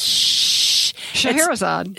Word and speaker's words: Scheherazade: I like the use of the music from, okay, do you know how to pronounce that? It's Scheherazade. – There you go.